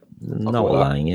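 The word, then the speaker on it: Noa Lang: Yeah, so we've had Jarrod Bowen, Marcus Thuram, Now Lang, is that how you say it? – Now Lang, yeah.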